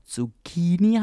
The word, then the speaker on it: zucchini: Das äh, wird aber wie bei Pinocchio wie ein K ausgesprochen, deswegen es auch Zucchini heißt mm. und mhm. nicht Zucchini.